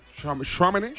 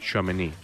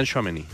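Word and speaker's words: Tchouaméni: Y Shameni, pues de renqueante, supongo. – Esto si fueran los tiempos de Zidane, eh, diría… ¿Cómo le pronuncias? Chouameni. – Chouameni. – O, o Shameni.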